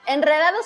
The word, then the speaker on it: enredado: Enredado, sin embargo, no, porque después de una N, esa R sí se pronuncia como fuerte.